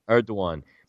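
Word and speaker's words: Erdoğan: But Erdogan, remember how you pronounce his name? I got this off Google. – Erdogan, yeah. – Erdogan.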